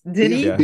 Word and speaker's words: Denis: Dennis. It's spelled the French way. Just yes, Denis. – Be a French.